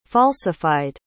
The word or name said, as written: falsified